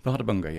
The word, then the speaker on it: Bárðarbunga: Bárðarbunga. – Bárðarbunga, yes.